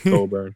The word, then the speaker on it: Cockburn: Winthrop, just like it's Kofi Cockburn and not Coburn. There's no way you can pronounce that Co the way it's spelled.